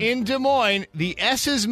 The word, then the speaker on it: Des Moines: Violetta moves to Des Moines. She lasts 30 days. – I love corn. – 30 days. – They got a good slogan in Des Moines. It's like where the S is silent, but the nightlife isn't, or something like that.